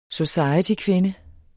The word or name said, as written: societykvinde